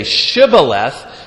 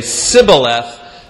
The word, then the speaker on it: Shibboleth: And if you say Shibboleth, well, that's how most of the groups pronounce it. But if you say Sibboleth, that's a sure indication that you're an Ephraimite.